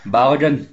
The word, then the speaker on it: Balogun: I'm going for a wee on Balogun, Craig, is that right? – Yeah, sounds right to me.